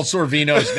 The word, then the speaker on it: Sorvino: You could teach Manny Servina some new words. – You just absolutely butchered Paul Sorvino's name.